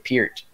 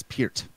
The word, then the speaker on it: Peart: I mean, yeah, obviously I never, like, played drums or anything, but I've always liked Neil Peart, and you've been pronouncing it Peart. Is that how you actually pronounce it? – From what I've heard over the years in musician circles, that it actually is Peart. – Hmm. – Wow. – That's what I've heard. – Yep. Interesting. I don't like it. I'm going with Peart.